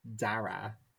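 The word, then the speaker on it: Darragh: I feel like it's darg. – It is neither Darha nor Darg. It's pronounced Dara. Dara. You don't Dara. Pronounce GHs as G sounds in Irish.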